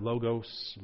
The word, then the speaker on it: logos: That Greek word, there is logos, L-O-G-O-S. You could pronounce it logos, logos.